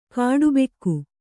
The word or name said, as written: ಖಟಬಾಕಿ